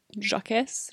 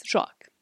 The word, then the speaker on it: Jacques: What is the French version? How do you pronounce it? Jacques? Jacques. Jacques. Jacques Delannoy. Mm-hmm. That is definitely correct.